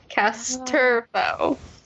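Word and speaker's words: Casterfo: But the emphasis is on the second syllable. Castorfo.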